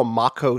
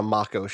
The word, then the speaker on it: Mako: Think I'm on Wikipedia, and it says the A is the A that you pronounce in father. So, yes, I think it's a Mako shark and not a Mako shark, which this means there must be some Maori?